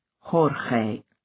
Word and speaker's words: Jorge: "Hey, Jorge, how's it going?" – Blaine pronounced it the Spanish way, Jorge.